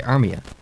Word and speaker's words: Armia: Yeah, Sportsnet, when they're calling his name, they say Joel, which is right, and they say Armia. – Yeah. – If you're going to pronounce the first name right, then at least pronounce the last name right and say Armia. Anyway.